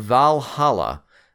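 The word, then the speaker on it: VA-11 HALL-A: It's called Valhalla, but it's spelled weird. It's V-A-1-1-H-A-L-L-A, but it's Valhalla for all practical purposes.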